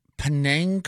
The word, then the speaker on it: Penang: Penang curry? – Mm-hmm.